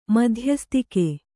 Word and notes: ಮಧ್ಯಸ್ಥಿಕೆ: madhyasthike

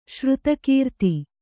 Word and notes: ಶ್ರುತಕೀರ್ತಿ: Kannada

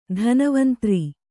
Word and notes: ಧನ್ವಂತರಿ: dhanvantari

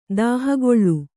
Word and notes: ತೋಹುಗೊಳ್: Kannada

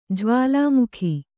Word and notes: ಜ್ವಾಲಾಮುಖಿ: jvālāmukhi

